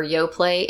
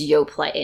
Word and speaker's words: Yoplait: And then there was Yoplait, and it was in that little slender, tall, kind of tapered container, and it was like luxurious, right? – Fancy with the silent T.